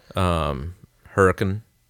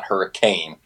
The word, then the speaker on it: Hurricane: Okay, because it's not hurricane; it's Hurricane.